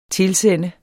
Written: TILSEND

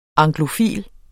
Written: anglofil

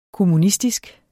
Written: kommunistisk

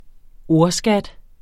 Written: ordskat

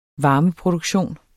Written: varmeproduktion

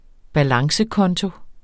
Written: balancekonto

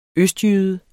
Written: østjyde

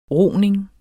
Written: roning

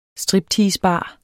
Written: stripteasebar

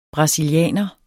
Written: BRASILIANERE

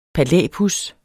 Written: palæpuds

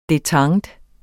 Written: detente

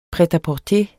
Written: pret-a-porter

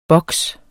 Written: boks

